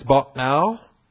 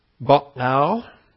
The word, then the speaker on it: Ba'al: Ba'al. Ba'al. It's the pronunciation as we have it. These three consonants, the Beth, the Ayin, and the Lamed. Ba'al. When you transliterate it, B-A, apostrophe, A-L.